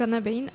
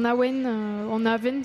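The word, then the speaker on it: abhainn: Donc Anne Abbein, ça se prononce pas comme ça normalement, mais c'était plus simple comme ça. Ça se prononce en Awen, ça dépend le coin d'Irlande.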